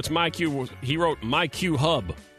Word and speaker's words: myQ: So the app, it's not a typo. It's pronounced MyQ.